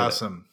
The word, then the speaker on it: Basim: It's been so long since I played Basim.